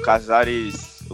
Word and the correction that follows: Cáceres: O Cáceres foi pra esquerda e o Varela foi pra direita, né?